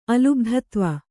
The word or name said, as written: ಅಲುಬ್ಧತ್ವ